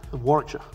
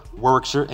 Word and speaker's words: Warwickshire: He originally hails from Warwickshire, England. Did I say that right? – Warwickshire. – Warwickshire, England.